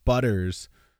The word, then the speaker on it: Butters: Butters is the name of the band, and I asked him, how do you pronounce that? And he said, it's just like Butters from South Park.